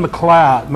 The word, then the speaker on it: McLeod: Kevin McLeod, McLeod, M-C-L-E-O-D. McLeod, is that how you pronounce that? McLeod? Huh? McLeod? McLeod? Okay.